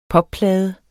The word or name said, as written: popplade